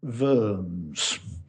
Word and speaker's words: Worms: And they gathered together and they called it the Diet of Worms, pronounced Worms, the Diet of Worms.